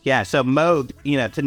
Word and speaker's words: Moog: And even now I kept having to remind myself, Moog.